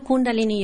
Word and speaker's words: Kundalini: I do Kundalini yoga. – I can't even pronounce it. – Kundalini yoga. – Okay. I pronounce that totally wrong. Kundalini, that actually sounds... – Kundalini, yes.